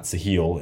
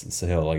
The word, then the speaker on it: Sahil: I know I've mentioned Greg Eisenberg and Sahil Bloom. I pronounced his name wrong. It's not Sahil, it's Sahil, I guess.